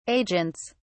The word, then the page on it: agents: British English